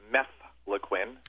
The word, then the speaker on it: mefloquine: It's mefloquine. Mefloquine. It's M-E-F-L-O-Q-U-I-N-E.